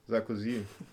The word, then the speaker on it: Sarkozy: Sarkozy.